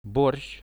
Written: borsh

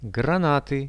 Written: granaty

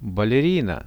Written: balerina

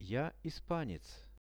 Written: ja-ispanec

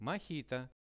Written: mohito

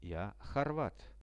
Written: ja horvat

